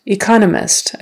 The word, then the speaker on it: economist: Economist, economist, again, second syllable stress.